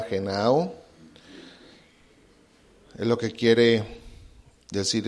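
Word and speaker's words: genao (γεννάω): La primera palabra donde dice que el que no naciere, naciere, viene de una palabra griega que es genao, gena, gena, o. Es con doble N, gena, acento en la A y al último la O, gena, gena, o.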